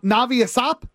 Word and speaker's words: Ashap: A-S-H-A-P. – Ashap. Navi Ashap? – Ashap, yeah.